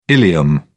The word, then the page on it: ileum: British English